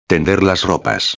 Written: tender las ropas

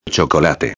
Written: chocolate

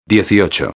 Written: 18

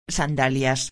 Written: sandalias

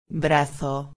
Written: brazo

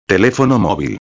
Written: telefono movil

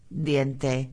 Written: diente

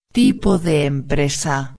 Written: tipo de empresa

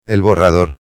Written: el borrador

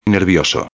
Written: nervioso

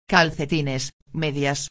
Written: calcetines medias

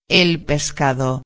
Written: el pescado

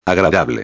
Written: agradable

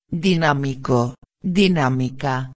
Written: dinamico a

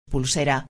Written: pulsera

